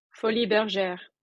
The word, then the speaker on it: Folies Bergère: Folies Bergère.